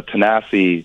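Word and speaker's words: Thanasis: And, you know, even Giannis is incredible, but, you know, Tanasi, is how you say his name in Greek, his oldest brother, is truly one of the inspirations of the family.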